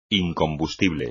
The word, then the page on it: incombustible: espagnol